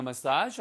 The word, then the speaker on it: massage: Am I supposed to be sore after getting a massage? 하고 물어보는 거겠죠. 발음 주의하세요, 이거. 메시지 그러면은 전달하는 메시지고요. 예, 그 다음에 전달 사항인 거죠. 얘는 massage예요. massage. 발음은 두 가지입니다. massage 하셔도 되고 massage 하셔도 돼요. 그래서 저는 massage를 더 선호하고요.